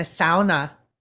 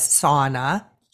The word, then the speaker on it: sauna: And it's important that, you know, the image that keeps coming to heart for me is like, you know, I grew up in the sauna culture, mispronounced as sauna. Sauna, it's a Finnish word.